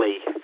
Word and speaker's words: Lee: It's Lima Echo Echo Lee, I'm currently in Vale Park in New Brighton.